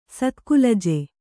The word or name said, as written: ಸತ್ಕುಲಜ